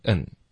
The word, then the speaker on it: and: 所以首先我们会把 and 弱读成 n OK, 然后就连读 and you, 所以它就会简单多了。